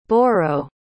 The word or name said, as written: boro